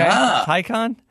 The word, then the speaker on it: Taycan: And of course, we talked about this as well, but the Taycan? – Yeah, it rhymes with icon, according to Edmonds. – Okay, Taycan.